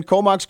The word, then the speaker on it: Comox: Comox, is that how you say it? Yeah, yeah, yeah. That's where we're in Comox, Courtney Campbell River.